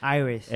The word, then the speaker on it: Iris: Se debe pronunciar Iris, ¿no? – Iris.